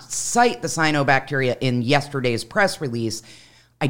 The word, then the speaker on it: cyanobacteria: how do you say it, cyanobacteria. – correct.